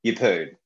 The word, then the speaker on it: Yeppoon: Yeppoon.